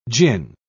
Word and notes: djinn: British English

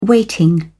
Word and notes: weighting: British English

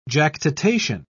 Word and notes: jactitation: British English

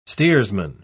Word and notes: steersman: British English

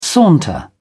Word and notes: saunter: British English

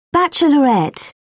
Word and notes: bachelorette: British English

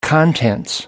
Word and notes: contents: British English

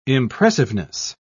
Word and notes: impressiveness: British English

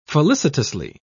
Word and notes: felicitously: British English

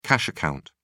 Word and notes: cash account: British English

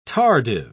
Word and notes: tardive: British English

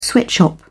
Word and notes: sweatshop: British English

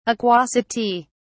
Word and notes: aquosity: British English

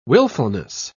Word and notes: willfulness: British English

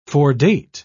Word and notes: foredate: British English

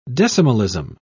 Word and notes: decimalism: British English

